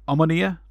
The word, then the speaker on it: Omonia: Omonia? – Yeah, Omonia, they're from Nicosia.